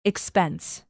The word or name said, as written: expense